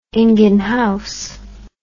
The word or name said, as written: ingenhousz